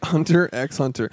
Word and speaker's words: Hunter x Hunter: I am 2.5 seasons into Hunter x Hunter, which I believe is pronounced just Hunter. – Hunter x Hunter.